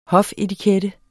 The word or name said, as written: hofetikette